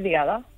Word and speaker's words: Diada: Feliz Diada.